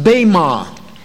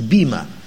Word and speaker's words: bema: See, that word judgment seat is the translation of one Greek word pronounced bema. In English, we would transliterate it and give the E a sound of an E like we do in English, bema. But bema is the word.